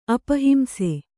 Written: ಅಪಹಿಂಸ

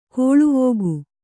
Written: ಗಾಲಿವಕ್ಕಿ

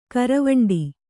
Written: ಗೋರಿವೇಂಟೆ

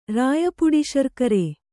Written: ರಾಯಪುಡಿಶರ್ಕರೆ

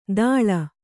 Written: ದಾಳ

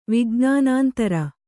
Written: ವಿಜ್ಞಾನಾಂತರ

